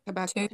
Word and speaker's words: Habakkuk: Habuk, is it Habukuk? I can never say it right. Habukuk Habakkuk.